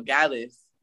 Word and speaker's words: Gallus: Nah, see, you're saying it right though. Gallus. Because it's like Gallus. The guys. Like them guys. Gallus. And then it's IS at the end, so you know it's Gallus.